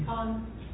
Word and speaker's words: Peicon: Pecan. – What is it? – Pecan, only it's got spelled like pecan. – Okay. No, P-E-I-C-O-N. – K-O-N. K-O-N, yeah.